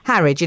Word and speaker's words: Harwich: The biofuel company Firefly plans to develop the poo plant in, do we pronounce it Harwich? I've never heard of this place. – Yes. – Harwich in Essex.